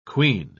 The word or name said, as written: queen